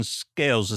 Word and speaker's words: Scales: I don't recall how many followers you have, but a guy I met on TikTok named Sebastian Scales. Is that how you say your last name? – Yeah, scales like a fish.